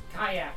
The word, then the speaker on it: Kiya: K-I-Y-A. – K-I-Y-A. – So is it Kira or Kaya? – Kaya.